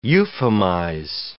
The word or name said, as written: euphemize